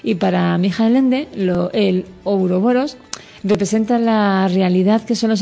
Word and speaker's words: Ouroboros: A ver si lo digo bien. Ouroboros. – Los uroboros. – Eso,